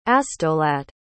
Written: astolat

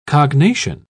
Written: cognation

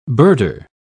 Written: birder

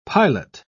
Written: pilot

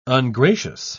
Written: ungracious

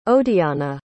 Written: odiana

